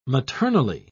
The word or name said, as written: maternally